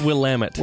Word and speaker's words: Willamette: It's commonly pronounced Willamette. It's Willamette.